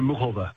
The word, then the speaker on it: Muchova: but I can't help but bring up that Carolina Muskova? Is that how we say her name? – And she says Carolina Muchova. – Muchova. – She pronounces it.